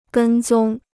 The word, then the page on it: 跟踪: gēn zōng